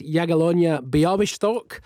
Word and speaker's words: Jagiellonia Białystok: They're in Poland to play the first leg of their tie against Jagiellonia Białystok.